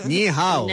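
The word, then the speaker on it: Ni hao (你好): Ni hao.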